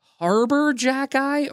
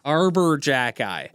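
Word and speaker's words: Arber Xhekaj: I still look at a Nick Robertson getting absolutely pushed around by, what's it, how do you say his name? Is it Harbor Jack Eye or Arbor Jack Eye? I say Arbor. Arbor Jack Eye.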